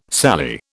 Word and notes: Sally: British English

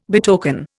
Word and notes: betoken: Indian

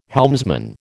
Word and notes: helmsman: American English

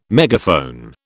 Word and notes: megaphone: British English

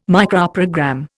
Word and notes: Microprogram: Indian

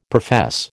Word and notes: profess: American English

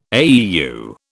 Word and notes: AEU: British English

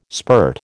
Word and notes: spurt: American English

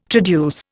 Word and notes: traduce: Indian

